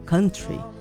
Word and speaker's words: country: Que tal alguém falar corretamente a palavra country? A pronúncia certa é country.